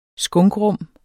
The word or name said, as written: Skunkrum